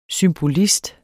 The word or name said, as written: symbolist